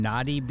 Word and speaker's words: Knotty: And people could purchase your creative creations from Nadi Bead on Instagram? – Yeah. – Cool. – Yeah, that's some. It's spelled like knot, N-A-N, or K-N-O-T-T-Y, so like Nadi Bead.